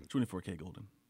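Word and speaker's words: 24kGoldn: Number seven, Mood by, is it 24K Golden or 24 Karat Golden? – 24K Golden. – It's K? – You just That's say how K? I've always heard it pronounced, yeah.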